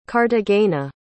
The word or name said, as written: cartagena